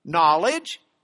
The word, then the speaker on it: knowledge: Sometimes we change it to a K. So we have knowledge. But we keep the K silent as well.